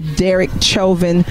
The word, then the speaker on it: Chauvin: is the reading of the verdict in the Derek Chauvin trial. Am I saying that right, Judge Harry Sutherland? – Yes, you are. Yeah. That's correct.